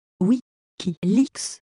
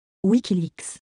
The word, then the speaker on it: WikiLeaks: Est-ce que vous pourriez prononcer Wikileaks correctement, s'il vous plaît Oui, Wikileaks. Wikileaks.